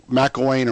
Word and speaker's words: McElwain: McElwain or McElwaith, I'll mispronounce his name. But I think you hit it the first time and then messed it up the second time.